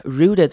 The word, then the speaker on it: rooted: Rooted 这个字要注意的是它中间的 T，因为 T 的 T 结尾的字后面接 ED 的时候，T 通常都会念成 d 的声音，所以我们会念成 rooted 而不是 rooted。